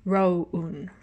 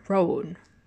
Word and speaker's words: Rowoon: Um, so I've never known how to pronounce his name, but if I see it in Hangul, it's actually Rowoon, Rowoon, Okay.